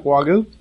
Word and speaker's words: Wagyu: And am I saying that right, Wagyu? – That's correct. That's correct.